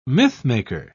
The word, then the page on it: mythmaker: British English